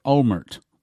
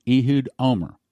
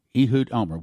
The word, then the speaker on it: Olmert: And in Israel, they pronounce it, now we may pronounce it Olmert, with a T on the end, the T is silent. Israelis pronounce his name Ehud Omer, Ehud Omer.